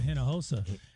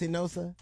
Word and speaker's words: Hinojosa: Man, as many Mexicans you deliver, you don't know it's Hinojosa? – It's not Hinojosa. – Okay, look at it, man. Look at it. Okay. – Hinojosa. Right. Hinojosa is Hinojosa. – Right. Hinojosa. – You're forgetting a syllable.